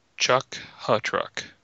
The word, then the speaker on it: Chukha-Trok: The Chuck Ewok. Chuck, like Chuck the Truck. Yeah, it's Chuck Ha Truck. So C H U K H A T R O K. Chuck Hutruck.